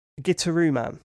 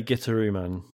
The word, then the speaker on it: Gitaroo Man: Gitaroo Man. – Gitaroo Man? – Yeah, well, in the game they do say Gitaroo Man.